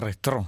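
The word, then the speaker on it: retrò: Ecco, alcuni chiedono se l'accento vada sulla E o sulla O. Se si trova in entrambe le forme, perché la forma retro con E con accento acuto è chiaramente quella che si rifà alla grafia del francese. La seconda parte, italianizzata, invece è senza la E accentata ma con la O con accento grave. – Sì, per far capire che si legge retro, insomma, perché non sa il francese.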